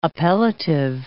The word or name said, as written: appellative